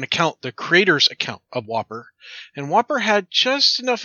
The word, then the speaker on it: WOPR: Well, it was a NORAD computer called the War Operation Plan Response, pronounced like the cheeseburger, the Whopper.